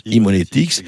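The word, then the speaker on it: Haemonetics: C'est ça, ça se prononce Emonetics.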